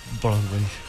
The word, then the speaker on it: Broadway: ¿Se pronuncia así? – Broadway. – Bueno, usted pronuncia mejor que yo. – No, pero lo pronunciaste excelente, Sandrín.